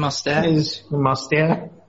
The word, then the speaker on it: Mostert: It's the French pronunciation, Mostert.